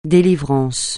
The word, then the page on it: délivrance: français